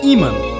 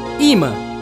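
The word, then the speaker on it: Yma: E para encerrar esta seleção do som temporâneo, só com novas caras da música brasileira, ouviremos Ima. Ima, que se escreve Y-M-A, é o nome artístico da Yasmin Mamédio, que em 2019 estreou com o álbum Par de Olhos.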